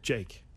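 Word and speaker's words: JVKE: And but it's J-V-K-E, but it's pronounced Jake. – Oh, I did not know that. I would have called him Jvik.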